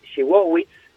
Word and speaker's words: Szydlowicz: Y en realidad en polaco es Siwowicz.